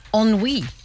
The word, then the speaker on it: ennui: The word is ennui, and it's spelled E-N-N-U-I, but pronounced ennui. – E-N-N-U-I.